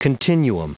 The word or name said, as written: continuum